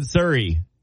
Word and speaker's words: Surrey: Robert in Surrey.